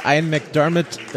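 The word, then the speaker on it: Ian McDiarmid: Und ich, ich muss aber auch sagen, ähm, für das, was äh, Ian McDermott, äh, so spricht man den übrigens aus,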